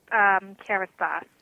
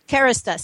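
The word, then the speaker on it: Kérastase: Has also recently been brought on by Kerastase. Did I pronounce that right? Just come in before I continue your introduction. – Hi there. It's actually pronounced Kerastase. – Kerastase.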